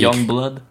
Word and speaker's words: Yungblud: Youngblood. – Ouais, c'est Y-U-N-G-B-L-U-D. Donc c'est Youngblood.